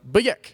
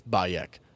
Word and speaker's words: Bayek: And, like, there's, like, a running joke throughout the game is no one pronounces his name right, like the Greeks and the Romans that he deals with. – Right. – He's always, like, they're, like, Bayek. And he's, like, it's Bayek. Bayek. And he's always just, like, and, like, walks off and, like, frustrated.